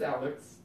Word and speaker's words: Alex: Is it Alex or Alec? – That doesn't matter anymore. – It's Alex. – Is it? – Yeah, it's Alex Trebek.